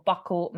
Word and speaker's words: buccal: buccal massage.